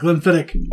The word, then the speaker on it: Glenfiddich: Bottle of Glenfiddich.